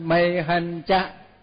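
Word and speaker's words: mayhañca: Còn mây mây hân, mây hân cha, đúng ra là mây hân với là cha, thành nó đọc là mây hân cha, tức là đến đến cho cha mẹ này.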